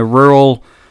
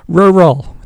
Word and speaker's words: rural: Rural. It's a hard word to say, isn't it? – Yeah, did I not say it right? – No, you said it right. You said it perfectly.